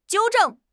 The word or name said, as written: 纠正